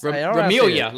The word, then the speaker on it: Ramelia: I'm going to say RSR, Ramalia Sportscast. – Ramelia, like Familia.